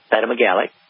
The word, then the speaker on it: cytomegalic: Yeah, cytomegalic.